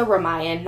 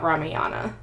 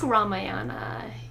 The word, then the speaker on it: Ramayan: It's also Ramayan, not Ramayana. – Ramayan? But the book Ramayana, you know? – Is Ramayan. – Ramayan? – Yeah. – So is it Ramayana? – It's, no, Ramayan. – But how do you say the book name? – R-A-M-A-Y-A-N.